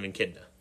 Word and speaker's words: echidna: Knuckles is an echidna. – Okay. – Yeah. There we go. Okay. Took me forever to learn how to pronounce that word, but that's how it's pronounced.